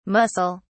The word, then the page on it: mussel (s): British English